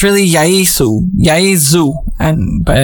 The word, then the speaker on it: Yaesu: And by the way, you're supposed to pronounce it Yaesu, but Americans, we say Yaesu.